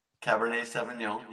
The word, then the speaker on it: Cabernet Sauvignon: But tonight I'm drinking a 2018 Cabernet Sauvignon. Right? Is that how you say it? I'm saying it wrong, ain't I? – Cabernet. – Yeah. Cabernet. – Cabernet Sauvignon, yeah.